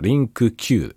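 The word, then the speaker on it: LingQ: リンク Q ってやつだね。リンク Q。で、リンクって読むんですけど、リンクの後に Q、アルファベットの Q がついてるやつ。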